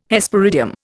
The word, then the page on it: Hesperidium: Indian